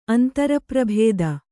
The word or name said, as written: ಅಂತರಪ್ರಭೇದ